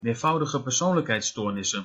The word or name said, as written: meervoudigepersoonlijkheidsstoornissen